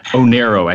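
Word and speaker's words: Oneiro: And Cosimo has some different companies, including Gecko, Novalea, and Onero. Am I saying that right? – Onero, actually. It's Greek for dream.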